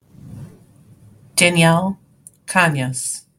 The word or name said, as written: Danielle Grassia Canas